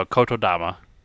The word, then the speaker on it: Kotodama: So Kotodama, pretty much as it sound, analog.